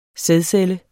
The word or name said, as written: sædcelle